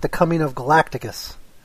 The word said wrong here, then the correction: Galactus: from Fantastic Four? – Galactus. By the way, is the correct pronunciation? – What?